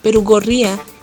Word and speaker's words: Perugorría: Carlos, ¿cómo se pronuncia tu apellido? Perugorría. Perugorría. Perugorría, sí. Eh, no importa, en algunos, Sí, es complicado, le, le cambian el acento.